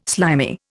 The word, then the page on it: slimy: Indian